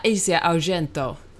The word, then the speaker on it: Asia Argento: Vänta, kan, du hade ett klipp där med, där vi fick höra uttalet. Du sa alltså, du, du, du googlade till och med upp det för du var osäker på hur det skulle uttalas. – Men hon sa så här, Asia Argento.